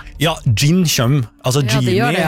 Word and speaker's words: jinn: Gin tonic, ja. Nei. Ja, det skrives med jod. J-I-N-N. Gin. Men også det uttales gin. For på engelsk skrives det med D først. Gin.